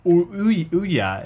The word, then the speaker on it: Ouya: Basically, Ouya. Apparently, it rhymes with Booyah.